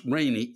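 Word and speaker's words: Rene: Now, if the fact it's pronounced rainy isn't enough of a pain in the ass, I am a man. The fact that it's spelled R-E-N-E, I have to deal with people thinking I am a woman.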